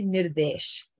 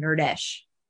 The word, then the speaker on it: Nirdesh: It's literally Nirdesh, right? It's Nirdesh, N I R D E S H but because Devi is so like Americanized or naturally like English speaking, she can't pronounce it right. So she calls him nerdish, which like has the word nerd in it.